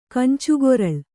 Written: ಕಂಚುಗೊರಳ್

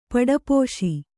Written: ಪಡಪೋಶಿ

